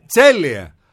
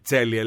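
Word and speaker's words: Celje: Τσέλιε. Γιατί κάτι θες να πεις εσύ και μετά να με στριμώξεις τώρα. – Ναι. Πώς λέγεται το Τσέλιε? – Τσέλιε λέγεται.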